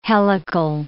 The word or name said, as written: helical